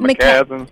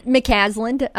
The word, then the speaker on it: McCasland: Am I saying his name right, McCaslin? – McCasland, yes. And you know, it was kind of one of those things where the momentum grew throughout the year.